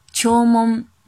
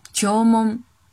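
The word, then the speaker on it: 聴聞: 读作梦的时候呢，单词是，比如听闻，刚刚听闻一件非常可怕的事情的听闻，这时候读作日语秋梦，秋梦，秋梦，写成日语的汉字是听闻的繁体字。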